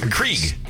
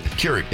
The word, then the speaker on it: Keurig: Johnny brought in a coffee maker because Krieg. – Krieg. – Keurig.